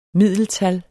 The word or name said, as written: middeltal